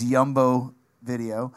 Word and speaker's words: Jumbo Video: We didn't pronounce the J. It was the Winnipeg thing. So it was Jumbo Video.